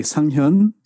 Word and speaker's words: Sanghyun: Sanghyun. – Sanghyun. Okay, so, got it. – Hyun is like a little bit like a H sound.